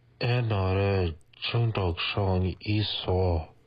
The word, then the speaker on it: 중독성: And then, 이 노래 중독성 있어.